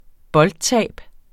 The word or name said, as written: boldtab